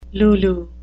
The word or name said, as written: lulu